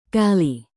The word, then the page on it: kali: Indonesian